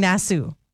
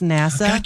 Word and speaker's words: Nassau: But, if my next vacation is to go to NASA, Bahamas, right away I was saying Nassau, because I didn't know it was pronounced NASA.